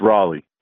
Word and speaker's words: Raleigh: Oh, it's Raleigh. Yeah, definitely Raleigh. I haven't heard anybody pronounce it Raleigh before, so I go with Raleigh, North Carolina.